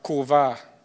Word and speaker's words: Qavar: The title of this message is the word uh, Korvar. It's spelled Q A V A R.